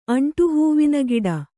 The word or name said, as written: ಅಂಟುಹೂವಿನಗಿಡ